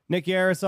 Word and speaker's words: Nick Yarris: We got Nick Yarris on.